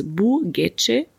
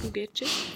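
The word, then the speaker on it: Bu Gece: Auch den hatten wir schon mit, ich glaube, man spricht es Bugece aus. – Würde ich auch so aussprechen, ja. – Bugece.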